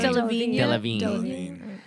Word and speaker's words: Delevingne: How do I say her name? – Delevingne.